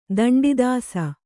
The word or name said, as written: ದಂಡದೋಸ